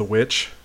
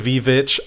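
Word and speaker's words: VVitch: It's the witch, the V-vitch. I know it's spelled that way, but colon, was it good?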